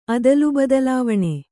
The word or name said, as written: ಅದಲುಬದಲಾವಣೆ